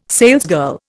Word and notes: salesgirl: Indian

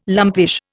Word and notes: lumpish: Indian

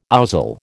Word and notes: ouzel: American English

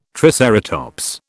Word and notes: triceratops: British English

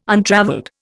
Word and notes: untravelled: Indian

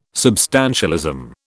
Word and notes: substantialism: British English

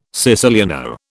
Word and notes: siciliano: British English

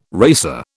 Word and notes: racer: British English

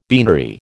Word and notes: beanery: American English

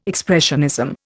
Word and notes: expressionism: Indian

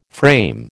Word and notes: frame: American English

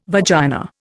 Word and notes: vagina: Indian

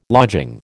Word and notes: lodging: American English

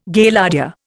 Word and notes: Gaillardia: Indian

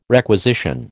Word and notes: requisition: American English

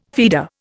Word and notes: feeder: Indian

